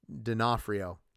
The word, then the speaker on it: D'Onofrio: The Kingpin seemed like he was a CGI guy, or at least Dion Fario was enhanced by CGI. I'm saying that wrong. D'Onofrio. Anthony has to correct me all the time on that.